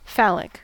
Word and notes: phallic: American English

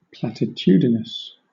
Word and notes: platitudinous: British English

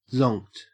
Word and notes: zonked: Australian English

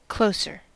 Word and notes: closer: American English

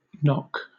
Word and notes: nock: British English

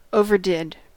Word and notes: overdid: American English

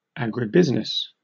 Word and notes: agribusiness: British English

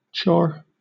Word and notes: chore: British English

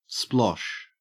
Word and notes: splosh: Australian English

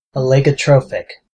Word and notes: oligotrophic: American English

